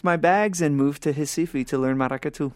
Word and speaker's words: Recife: So you went to what looks like Recife, but is pronounced Recife. – Recife.